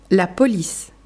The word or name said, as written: La police